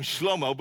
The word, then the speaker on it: Shlomo: I kept calling him Shlomo because that's how you say it in Hebrew, Shlomo.